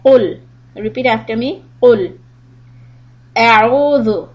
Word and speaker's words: قل: Now, when we start, the first word is قل. There's a difference between the pronunciation of the قاف with the two dots and the كاف. If you say قل, it means to eat. And قل means say.